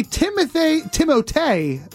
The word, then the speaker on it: Timothée: There was a Timothée, he told us, that's how you pronounce his name, Kevin.